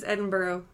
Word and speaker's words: Edinburgh: This is somebody that you might be doing in Edinburgh with Edinburgh screen with. – I'm pretty sure it's Edinburgh. I've been there in Scotland, and I'm pretty sure it's what you say, Edinburgh.